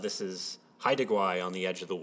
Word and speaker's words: Haida Gwaii: Haida Gwaii.